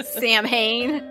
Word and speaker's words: Samhain: and Samhain. I almost fucking said it wrong. Thanks, Supernatural. – Samhain. – I'm coming for you, Kripke. You messed me up for years.